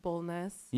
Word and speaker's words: Bulnes: They always say Rebecca Bulnz instead of like Bulnes.